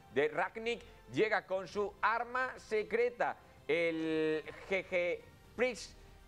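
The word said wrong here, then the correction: Gegenpressing: Bueno, pues ese sistema que el GG Pressing, ahora sí, pronunciado bien,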